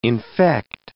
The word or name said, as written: infect